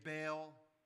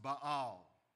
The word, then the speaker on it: Baal: Most people say Baal, but it should be pronounced Baal, the Canaanite deity.